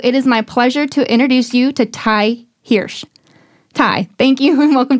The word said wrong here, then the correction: Hirsch: It's Hirsch. – Hirsch. – Like Hershey's, like the candy.